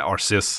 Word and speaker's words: Arceus: beskjed om at, det er, at ifølge engelske uttalelsesregler så skal det være en S. – Det skal være Arceus.